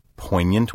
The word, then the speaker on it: poignant: Third, it may mean biting, cutting, acute, piercingly effective, poignant wit, poignant delight, a poignant critique. The odd spelling of poignant, with its silent G, comes from French.